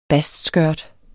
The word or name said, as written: bastskørt